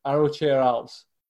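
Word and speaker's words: Arrochar Alps: And the people who are saying, apparently it's the Arrochair Alps. So there we go. That's apparently a better pronunciation.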